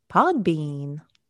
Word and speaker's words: Podbean: Podbean.